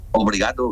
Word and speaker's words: obrigado: ¿Cómo, obligado, sí, ¿cómo se dice? Obligado es gracia, ¿no? – Obrigado, gracias. Sí, obrigado.